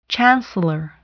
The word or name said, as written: chancellor